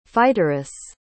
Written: fighteress

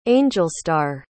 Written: angelstar

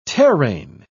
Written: terrain

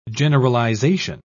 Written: generalization

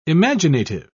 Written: imaginative

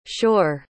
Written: shor

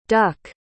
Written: duc

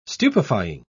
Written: stupefying

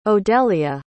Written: odelia

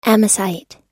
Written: amosite

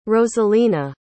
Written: rosalina

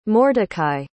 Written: mordechai

